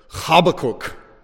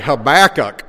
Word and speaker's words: Habakkuk: If you're a Hebrew speaker, a native Hebrew speaker, you'd probably say it Habakkuk. Habakkuk. If you're an American cowboy, you say Habakkuk. Habakkuk. That's how all of us Westerners say it. We get it totally wrong.